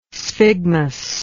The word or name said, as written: sphygmus